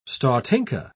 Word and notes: stotinka: British English

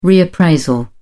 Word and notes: reappraisal: British English

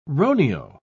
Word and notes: roneo: British English

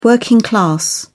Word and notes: working class: British English

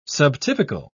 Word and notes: subtypical: British English

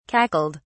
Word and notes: cackled: British English

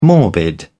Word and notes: morbid: British English

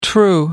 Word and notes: the true: British English

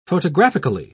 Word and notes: photographically: British English

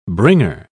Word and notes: bringer: British English

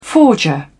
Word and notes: forger: British English

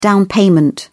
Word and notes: down payment: British English

